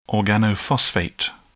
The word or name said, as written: organophosphate